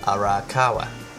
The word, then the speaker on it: Arakawa: It is written and drawn by mangaka Hiromu Arakawa. That time I actually said it right, yes.